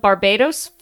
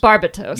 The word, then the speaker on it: Barbatos: Then there's the Tamashii Nation's Gundam Barbados figure. Barbatos.